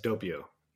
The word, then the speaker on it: Doppio: Uh, I believe Doppio Cafe. Is that am I pronouncing that right? – Yes, Doppio. Yes, Doppio for um, for a long time.